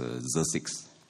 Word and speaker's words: Xerxes: In the first year of Darius, king of Xerxes, this is a very hard name to pronounce. I checked the internet, it's supposed to pronounce Xerxes.